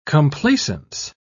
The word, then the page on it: complacence: British English